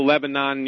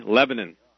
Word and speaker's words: Lebanon: There is no Lebanon, New Hampshire, only Lebanon. – Lebanon. – Lebanon. So, Lebanon.